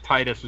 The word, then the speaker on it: Tidus: Oh, that's kind of what they did with Tidus as well, because officially, and I think there's a couple of lines in Kingdom Hearts 1 where it's actually pronounced this way, it was pronounced Tidus for a while, and then they gave up, and by Kingdom Hearts 2 it's pronounced Tidus when it's mentioned.